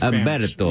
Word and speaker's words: Umberto: Umberto with a U or an H? – With a A silent H. – Okay. – That was my Spanish. – Umberto.